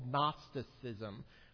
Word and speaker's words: Gnosticism: Their real problem has something to do with this thing called Gnosticism, with a silent G.